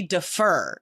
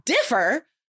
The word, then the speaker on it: differ: And then one of the students was like, that's not how you say it, because I've heard native speakers say defer. And I'm like, well, that's a different word. Like, that's an entirely different word. Differ, syllable, first syllable is stressed, and defer, that's like a, it's a different verb.